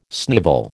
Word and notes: snivel: American English

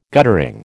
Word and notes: guttering: American English

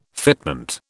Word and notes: fitment: British English

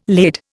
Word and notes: laid: Indian

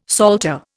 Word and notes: psalter: Indian

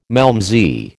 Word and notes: malmsey: American English

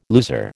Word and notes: loser: American English

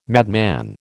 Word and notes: madman: American English

